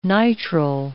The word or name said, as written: nitrile